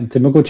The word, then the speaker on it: Temuco: Let me get if I say this right. Temuco, Chile. – Yeah, Temuco, Chile.